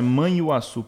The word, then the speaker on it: Manhuaçu: O cara sai do interior de Manhaçu, Manhuaçu, não consigo nem pronunciar, né? Manhuaçu, perdão.